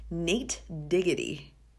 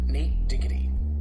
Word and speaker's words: Nate Diggity: I'm here to tell you the way it's supposed to be pronounced. It's called Nate Diggity. See what I did? See? Nate Diggity. D-I-G-A-D. Nate D-I-G-A-D. Diggity. Very clear, right?